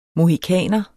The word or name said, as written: mohikaner